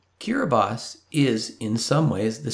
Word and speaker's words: Kiribati: Even though it's spelled K-I-R-I-B-A-T-I, it's not pronounced Kiribati. It's pronounced Kiribati.